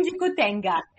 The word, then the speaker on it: Dikutenga: E agora a gente vai poder conferir um trechinho de Vou Te Levar, que acredito que seja pronunciado em Dikutenga. – Exatamente.